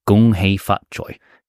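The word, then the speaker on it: Gong Hei Fat Choi: How do you say Gonghae Fatchoi? – Gonghae Fatchoi. – Gonghae Fatchoi. – Yeah. Happy New Year.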